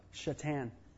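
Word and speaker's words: Šatan: His last name was S-A-T-A-N. Shatan. That's how they pronounced it.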